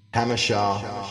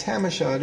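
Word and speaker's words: Jamacha: Hamishaw. Hamishaw starts with a J. We're like, what's that, Jamaica Street? No, it's Hamishaw, dude.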